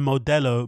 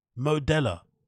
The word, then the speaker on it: Modelo: Have you heard anybody pronounce a Modelo Modelo in your entire life? Modella like I'm not even the biggest Modelo drinker in my in my life, right?